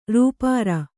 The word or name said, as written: ರೂಪಾರ